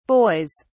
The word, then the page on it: boys: British English